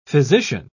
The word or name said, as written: physician